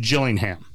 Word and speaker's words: Gillingham: It is pronounced Gillingham, right? You see G-I-L-L-I-N-G-H-A-M, right? So, mm-hmm. Gillingham, right? You would think that would be with a J, right? – Yeah.